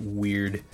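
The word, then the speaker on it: wyrd: the thing behind the scenes spelled like W Y R D. I think you pronounce it weird.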